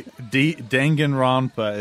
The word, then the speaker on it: Danganronpa: That's that's actually Danganronpa is how you say it, I guess. – I think he's having a stroke. – Is it Dang and Rampa? – Did you smell one burning word.